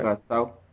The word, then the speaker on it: Curaçao: The islands are Curaçao, or Curaçao, am I saying that right? – Curaçao. – Curaçao, that's how you say it? – Curaçao. – Curaçao. – Yeah.